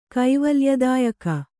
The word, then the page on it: ಕೈವಲ್ಯದಾಯಕ: kaivalya dāyaka